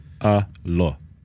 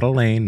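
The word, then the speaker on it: Alain: But when he was trying to say Allah's name, okay, Allah is Allah, Allah, Allah, Allah, not a lane, not a lawn, Allah.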